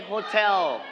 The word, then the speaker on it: hotel: Everybody say hotel. Everybody say it. Hotel. – Hotel. – Very good. All right. So we are going to straight on the second syllable.